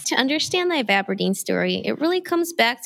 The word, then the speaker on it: ivabradine: Ivabradine.